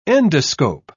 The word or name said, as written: endoscope